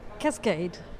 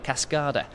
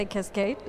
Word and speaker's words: Cascade: How do you pronounce the Cascade magazine? – Cascade. – Because some people, I've heard a lot of people call it Cascada. – Yeah, depends. When I speak English, I say Cascade.